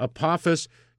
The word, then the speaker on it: Apophis: Yeah, I thought I read that Apophis, that's how they say it? – Yeah. – Apophis is going to pass 20,000 miles from the Earth, which is inside the orbit of some of these satellites, sure.